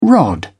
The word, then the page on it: rod: British English